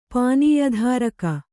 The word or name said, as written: ಪಾನೀಯಧಾರಕ